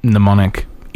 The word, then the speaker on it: mnemonic: Because if you want to use mnemonic in mnemonic, you have to use an M, but it would make more sense to be used as an N. Even though the M is kind of pronounced, it's just barely there. Mnemonic.